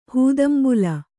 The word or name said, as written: ಹೂದಂಬುಲ